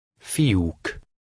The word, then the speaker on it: Fiúk: Number 19 has two vowels next to each other. You pronounce them as separate sounds. Try to read number 19. – Fiúk.